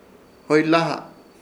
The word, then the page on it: hoylaha: Ishir Chamacoco